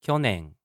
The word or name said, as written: kyonen